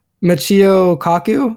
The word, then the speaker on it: Michio Kaku: Michio Kaku, is that how you say it? – Oh, yeah, yeah.